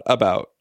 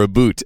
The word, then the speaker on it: about: So the word about has, or aboot, as our friend Sean might pronounce it, has a very clear accent pattern. It goes unstressed, stressed, about. – Right. – So the accent, the stress is on the second syllable of that word.